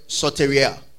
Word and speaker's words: soteria: Now, salvation comes from the Greek word soteria. Soteria is spelled S-O-T-E-R-I-A. Soteria. Say soteria. Yeah. S-O-T-E-R-I-A.